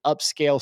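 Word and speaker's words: Upscayl: But I would still recommend, even if you're using some of the built-in upscaling tools, use a dedicated upscale software. There's one that I've been using, which is free, and it's called Upscale, but it's spelled funny. it's spelled U-P-S-C-A-Y-L. U-P-S-C-A-Y-L.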